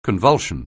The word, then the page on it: convulsion: anglais